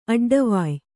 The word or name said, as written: ಅಡ್ಡವಾಯ್